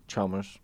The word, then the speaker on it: Chalmers: Chalmers. – Just like it's spelled.